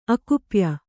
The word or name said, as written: ಅಕುಪ್ಯ